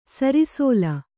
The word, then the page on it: ಸಿರಿಸಲ: sirisala